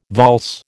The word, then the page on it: valse: American English